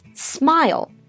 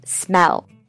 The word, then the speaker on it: smile: Remember, it's smile, not smell.